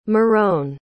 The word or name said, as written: marone